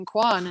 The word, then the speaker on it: Kwan: My name is Adrian Kwan, and you are watching Pageant. Can I applaud you for being able to say Kwan, because all Americans say Quan. – They say Quan.